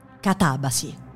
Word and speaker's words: katabasi: con l'accento elegantemente sdrucciolo. Katabasi.